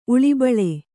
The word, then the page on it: ಉಳುಬಾಳು: uḷubāḷu